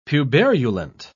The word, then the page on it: puberulent: British English